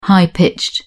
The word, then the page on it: high-pitched: British English